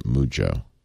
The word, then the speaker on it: Mujjo: M-U-J-O. – J-J-O. – Two J's. – M-U-J-J-O. – So that's actually not Mujo, but Mujo. – Mujo. – Yeah, say the J twice.